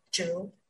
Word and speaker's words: Zhu: If you Google me as Emily Zhu, EB5, you can find me directly, or New Empire.